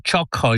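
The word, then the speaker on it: Xhaka: Was aber ist mit dem X? Text? Xylophon? Nein, ganz einfach. Chaka. Der Granit Chaka ist unser Captain und 146-fach Mittelfeldspieler.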